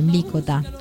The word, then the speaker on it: Mlícota: Vamos a escuchar una más de Jorge Mlícota, como él me enseñó que había que pronunciarlo.